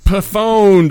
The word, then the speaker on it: pwned: Pwned. – Pwned.